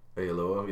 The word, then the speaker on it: Oyelowo: David Oyelowo. – Oyelowo, yep.